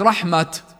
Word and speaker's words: رحمة: فالتاء هنا في كلمة رحمة رسمت بالتاء المربوطة، فإن وقفنا عليها، وهنا يجوز الوقف، فإننا ننطقها هاء فنقول وهب لنا من لدنك رحمة. لاحظ هناك رحمة لأنها كانت تاء مفتوحة لفظنا التاء، هنا لأنها تاء مربوطة لما نوقف عليها بنلفظها هاء، وهب لنا من لدنك رحمة.